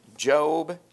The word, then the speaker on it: Job: It's toward the middle of your Bible, right before the book of Psalms, book of Job. It's spelled like Job, so that may be throwing a few of you off. You're like, that's not it. That's Job.